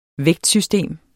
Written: vægtsystem

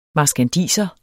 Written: marskandiser

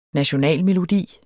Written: nationalmelodi

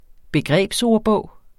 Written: BEGREBSORDBOGEN

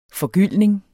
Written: forgyldning

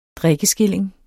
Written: drikkeskilling

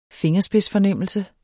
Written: FINGERSPIDSFORNEMMELSER

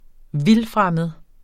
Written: vildfremmed

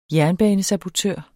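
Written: JERNBANESABOTØR

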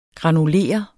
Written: granulere

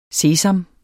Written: sesam

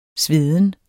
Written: sveden

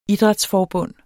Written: idrætsforbund

